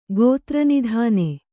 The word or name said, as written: ಗೋತ್ರನಿಧಾನೆ